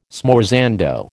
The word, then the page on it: smorzando: American English